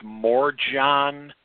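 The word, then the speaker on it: Morejón: Mackenzie Gore or Adrian – I believe it's pronounced Morjon – could get that slot.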